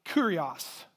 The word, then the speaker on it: kurios: In the New Testament, the Greek, the word for Lord is the word kurios, which is interesting. It sounds like the word curious, but that's not what it is.